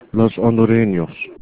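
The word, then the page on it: hondureños: Spanish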